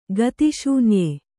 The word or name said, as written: ಗತಿಶೂನ್ಯ